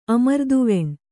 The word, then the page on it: ಅಮೃತವಾಣಿ: amřtavāṇi; kannada